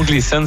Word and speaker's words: Gleason: Comment ça se prononce comme ça? – Ouais, c'est le mont Gleason.